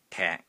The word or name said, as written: TEK3